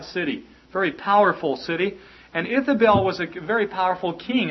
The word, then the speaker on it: Ithobal: His name was Ithabel. Ithabel the Second. That's spelled I-T-H-O-B-A-L.